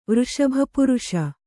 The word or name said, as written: ವೃಷಭಪುರುಷ